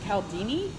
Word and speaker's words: Cialdini: Trying to see how, do you, how do you say the author's name? I think it's Cialdini. Okay, then I totally botched it.